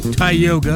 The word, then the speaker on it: Tioga: Tioga, as we pronounce it today, is a variation of the Iroquois word Dioga, which roughly translates to at the forks.